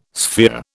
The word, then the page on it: sphere: British English